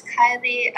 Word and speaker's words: Kyleigh: Hi, my name is Kylie. Spell it K-Y-L-E-I-G-H and last name L-E-F-E-B-V-R-E.